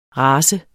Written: rase

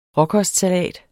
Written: råkostsalat

